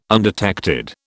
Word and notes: undetected: British English

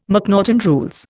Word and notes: McNaughten rules: Indian

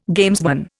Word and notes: gamesman: Indian

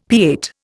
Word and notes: pâte: Indian

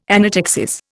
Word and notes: anaptyxis: Indian

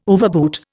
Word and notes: overboot: Indian

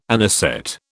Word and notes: anisette: British English

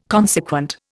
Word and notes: consequent: Indian

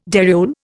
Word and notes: dariole: Indian

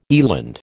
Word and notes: eland: American English

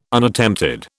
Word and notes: Unattempted: British English

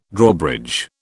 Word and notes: Drawbridge: British English

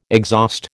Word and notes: exhaust: American English

